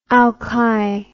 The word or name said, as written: alki